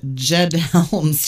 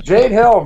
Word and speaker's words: Jade Helm: Well, and she called it Jed Helms. She kind of... Oh, yeah, that was the other thing. I almost laughed out loud when I was listening. She couldn't even pronounce it. I mean, who's running Jed Helm? Is it Jed Clampett? Jade Helm, brought to you by the Beverly Hillbillies.